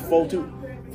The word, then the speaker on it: Fotu: L-E-K-I. – Okay. Fo-O-2? – No, it's just Fo-2. – Fo-2. Okay. – F-O-T-U. Fo-2. – That's the pronunciation. – It's Fo-2. That's spelled F-O-T-U.